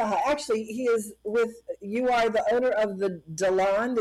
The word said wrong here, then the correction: DeLand: Is that how you say it, Corporation? – It's Dayland. – Dayland, okay. I knew I was going to say it wrong.